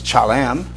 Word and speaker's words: chalam: You can see what the Hebrew letters look like, and it's pronounced as chalam, if you want to know it.